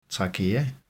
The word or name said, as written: trachea